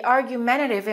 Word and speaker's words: argumentative: The boss mitigated the problem by firing the argumentative employee. Let's look at the two T's in the word argumentative. I didn't pronounce the first T, but the second T I pronounced like a flat T.